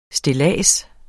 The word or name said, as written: stillads